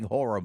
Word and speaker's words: horror: Unless it's in her house watching horror movies. That's what she's doing all week. – Two syllables. – Yeah. – Horror movies, yes.